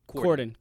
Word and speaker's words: Corden: The, am I saying this right? Corbin? What's his name? Corden. – Corden. D. It's D, yeah.